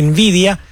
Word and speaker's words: Nvidia: Mientras tanto, la primera dama de los Estados Unidos, Melania Trump, anunció ayer una asociación estratégica con la empresa Nvidia, Nvidia se escribe, N-V-I-D-I-A, Nvidia, para incorporar la inteligencia artificial en las escuelas, en pleno debate sobre la regulación y los riesgos de esta nueva tecnología.